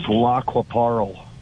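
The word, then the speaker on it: Lac qui Parle: It's pronounced Lockwaparl.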